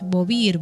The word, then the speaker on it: Beauvoir: Una persona que debatió mucho y brindó un montón de aspectos de información sobre este tema fue Simone de Beauvoir, que no sé cómo se pronuncia. Beauvoir, me dice acá Sherman.